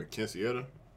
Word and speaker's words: quinceañera: And I might be filming again next month, but I'm doing a quinceañera. Hopefully I'm saying that right. – Little birthday party. – You got it.